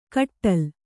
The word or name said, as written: ಕಟ್ಟೋಲೆ